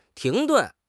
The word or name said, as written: 停顿